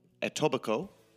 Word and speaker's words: Etobicoke: The place is pronounced Etobicoke, okay, which actually is now like a bustling city.